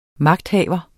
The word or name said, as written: magthaver